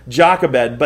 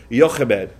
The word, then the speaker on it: Jochebed: the Hebrew pronunciation would have been Jochebed, okay? And it sounds like you're like coughing something out, and so I don't want to do that the whole time. Did I say that right, Grandma? Jochebed. All right, so it's a Y sound like phlegm, like Jochebed.